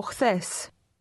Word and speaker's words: Χθες: Από χθες. Χθες. Χθες. – The beginning of this word sounds like the English letter H, but it's pronounced with more breath.